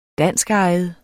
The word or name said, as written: danskejet